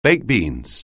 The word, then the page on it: baked beans: anglais